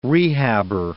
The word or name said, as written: rehabber